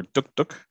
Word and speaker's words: Dug Dug: And one that I'm going to talk about is a film from India called Dug Dug, or Duk Duk, as it's, I think, properly pronounced.